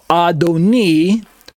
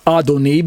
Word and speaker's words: Adoni: But on the left, we have Adoni, pronounced Adoni because of the vowel dot, the chirik, that shows up underneath the letter nun, the N letter, in the word Adoni, reading from right to left.